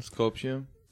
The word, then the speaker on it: Skopje: The following year in Skopje, sorry, I don't know how to say it. – Skopje. – All right, just edit. Skopje. Yeah.